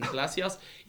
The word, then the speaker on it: Palacios: Ex-Wigan player, Wilson Palacios. You know, it's pronounced Palacio, okay? – Oh, very good.